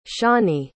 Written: shani

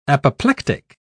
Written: apoplectic

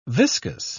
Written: viscus

